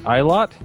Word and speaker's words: Aylott: Is it Aylott? – Aylott. It's Aylott. Like a whole big bunch.